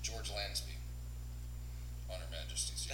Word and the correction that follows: Lazenby: Lazenby. – Lazenby.